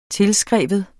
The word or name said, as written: tilskrive